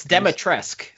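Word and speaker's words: Dimitrescu: It's Demetresque. So Demetresque. You don't pronounce the U, which is absurd.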